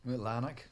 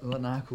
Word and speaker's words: Larnach: Larnach, a little bit Larnach will cover it.